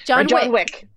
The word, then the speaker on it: Wick: And so they want to have the same thing to recognize like, yeah, well, what about, you know, John Vick or whatever. Am I even saying that right? Yeah, Wick. – That's right. – John Wick. – I don't know why I was going for Vick.